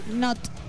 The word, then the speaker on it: Slipknot: Slipknot. – ¿Cómo termina la palabra? – Not. Ah. N-O-T. No con K, como pensabas.